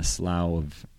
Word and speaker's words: slough: We've been in a slough of depression. – A what? – A slough. – What? – Slough. S-L-O-U-G-H, I think. Slough. It's like a depression. It's like a low.